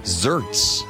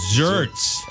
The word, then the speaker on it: xertz: Can you spell it? – Yeah. X-E-R-T-Z. – Oh. Wow. X-E-R-T-Z. – Zerts. – Zerts. – X-E-R-T-Z.